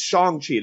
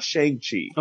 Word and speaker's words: Shang-Chi: No, it is Shang-Chi. It is not Shang-Chi. – Okay. – I'm pronouncing it the way he has pronounced it.